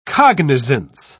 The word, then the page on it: cognisance: British English